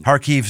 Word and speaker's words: Kharkiv: Kharkiv Sister City Partnership.